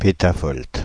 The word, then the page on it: pétavolt: Paris; [pe.ta.vɔlt]